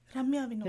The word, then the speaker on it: Ramya Vinod: Ramya Vinod.